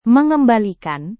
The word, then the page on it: mengembalikan: Indonesian